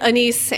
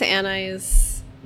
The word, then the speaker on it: anise: Yep, and anise, anise. – Anise. – Either way you want to say it.